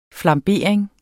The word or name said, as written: flambering